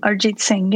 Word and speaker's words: Arijit Singh: Arijit Singh? – Yeah, no, that's not bad at all. Arijit Singh, yeah.